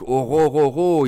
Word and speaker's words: Orhorhoro: ça se prononce assez facilement. En tout cas, c'est comme ça que le disent les Américains. Est-ce qu'ils le disent bien Je sais pas, mais Orororo, on va le dire comme ça.